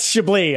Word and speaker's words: Chablis: That's Chablis. I'm like, Chablis?